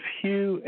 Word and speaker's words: Hugh: My name is Hugh, H-U-G-H. The last name, Trawlson, is spelled T like Tom, R-A-U-L-S-E-N like Nancy.